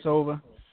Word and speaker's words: Ilyasova: Ilyasova. Did I say it correctly? – Ilyasova. – Ilyasova, yeah. Ilyasova.